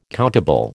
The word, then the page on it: countable: American English